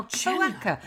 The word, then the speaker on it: cloaca: Cloaca. – Jenna, it's spelled like this. – Impressed. – C-L-O-A-C-A.